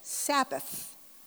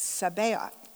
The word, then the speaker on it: Sabaoth: my brain wants to go, Sabbath, but it's not Sabbath, it's Sabaoth. And so let me tell you how to spell that. That's S-A-B-A-O-T-H. S-A-B-A-O-T-H.